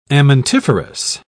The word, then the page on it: amentiferous: British English